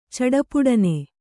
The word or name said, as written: ಚಡಪುಡನೆ